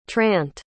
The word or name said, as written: trant